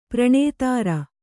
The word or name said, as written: ಪ್ರಣೇತೃ